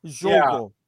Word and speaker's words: jogo: Was that a J at the front? Jogo. Yeah. Jogo. They pronounce the J in Portuguese.